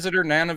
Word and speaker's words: Nana: Nana Visitor. How do you pronounce it? – I think it's Nana is how I've heard her say it.